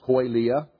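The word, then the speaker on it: koilia (κοιλία): First of all, the second bullet point, stomach or belly, is from the Greek word koilia, pronounced koilia, which is the same as the noun in Romans 16:18.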